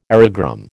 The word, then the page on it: aerogramme: American English